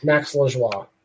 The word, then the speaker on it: Max Lajoie: Max Lajoie.